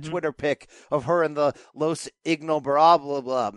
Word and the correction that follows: Ingobernables: Man, I always butcher that. – Ingobernables, which is obviously a shout out to Andrade, her real life boyfriend, apparently right now, who started that faction in Mexico.